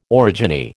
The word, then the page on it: orogeny: American English